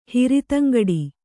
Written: ಹಿರಿತಂಗಡಿ

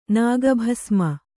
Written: ನಾಗಭಸ್ಮ

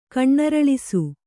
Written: ಕಣ್ಣರಳಿಸು

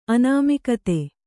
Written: ಅನಾಮಿಕತೆ

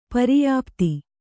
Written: ಪರ್ಯುಪ್ತಿ

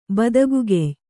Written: ಬದಗುಗೆಯ್

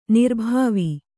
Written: ನಿರ್ಭಾವಿ

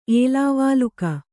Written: ಏಲಾವಾಲುಕ

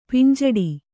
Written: ಪಿಂಜಡಿ

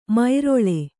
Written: ಮೈರೊಳೆ

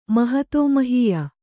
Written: ಮಹತೋಮಹೀಯ

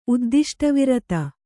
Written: ಉದ್ದಿಷ್ಟವಿರತ

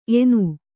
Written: ಎಣ್ಣೆ

